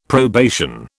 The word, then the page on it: probation: British English